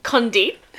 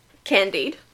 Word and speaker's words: Condit: Condit, the guy she was having an affair with. Not Candide. – Condit? – It's probably Condit, and I've been saying it wrong for a while.